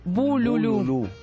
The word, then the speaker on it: bululú: Estaba escuchando música en el celular y de repente, buenas noches, era un audio del Bolulú, de una vez que habíamos ido con las chicas. – El Bolulú. – El Bululú. Lo dije mal, Bolulú.